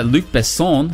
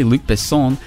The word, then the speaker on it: Luc Besson: And coming up on today's show, French director Luc Besson, I think I'm pronouncing that correctly, Luc Besson, his latest sci fi extravaganza, Valerian and the City of a Thousand Planets.